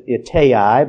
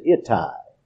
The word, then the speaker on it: Ittai: Now some might pronounce it Ittai, but a Jew would pronounce it Ittai, like Adonai, A-I, Ittai the Gittite.